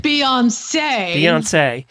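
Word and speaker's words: Beyoncé: Beyonce? – Yeah. She demanded her dressing room be kept... – Beyonce.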